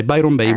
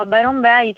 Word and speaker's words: Byron Bay: Byron Bay. Buongiorno. – Buongiorno a voi. Sì, hai detto bene, vivo a Byron Bay